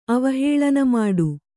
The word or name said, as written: ಅವಹೇಳನಮಾಡು